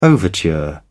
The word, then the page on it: overture: British English